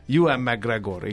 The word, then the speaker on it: Ewan McGregor: Ewan McGregor, igen. Ezt mondta nekem a kántor, hogy így mondjam. – Igen, így kell mondani.